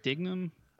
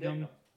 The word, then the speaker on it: Dignam: So, um, like I said, I have the scene where Costigan's getting grilled by uh, how do you say his name? Dignam. – I, have always called it Dignam. Dignam. – I've always said Dignam.